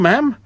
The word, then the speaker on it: ma'am: So, with a pip-pip and a three cheers and a here's to you, ma'am. Yes, it rhymes with ham and spam and jam, but not smarm or harm.